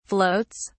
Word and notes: floats: British English

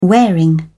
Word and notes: wearing: British English

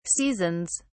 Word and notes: seasons: British English

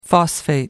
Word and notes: phosphate: American English

